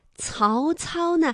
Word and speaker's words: 曹操: 像刚才说到了临下士啊，这个下，青铜刀的青，还有临家遗址的址，诸葛亮的诸，黄忠的忠，曹操的曹和操。那他们呢都是可以进行一个区分，比如说像下，它舌面啊是贴近硬腭来发音的，猪啊，它这个舌尖呢是要抵住或者是接近硬腭的前部来发音的，曹操呢是舌尖要抵住上齿背哎去发音的，曹操，大家注意一下他们的发音位置。